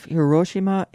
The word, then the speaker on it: Hiroshima: Trans-Pacific Memories of Hiroshima and Nagasaki.